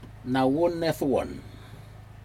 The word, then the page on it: nawun nedwun: Aneityum